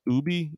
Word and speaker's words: ube: I guess it's pronounced ubi, U-B-E.